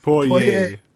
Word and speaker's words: Poirier: Yeah, all you gotta do is listen to Jim Ross say it. Poirier. – It just how it's spelled out and how you pronounce it is completely different, in my opinion.